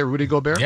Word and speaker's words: Gobert: Isn't that how you say it, Rudy Gobert? – Yeah, he's good.